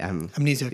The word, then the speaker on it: Amnesiac: Amnesiac.